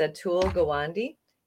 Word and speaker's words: Atul Gawande: I never pronounce this name correctly, but it's Atul Gawande, and that's the author of Being Mortal.